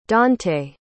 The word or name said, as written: dontay